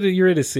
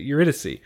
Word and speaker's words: Eurydice: But Eurydice. I think that's the right way to say it. Eurydice.